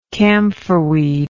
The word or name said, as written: camphorweed